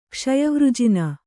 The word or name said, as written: ಕ್ಷಯವೃಜಿನ